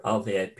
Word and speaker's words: Ove: I'd written A Man Called Ove, or Ove, people pronounce it a different way, completely and utterly different theme and brilliantly written.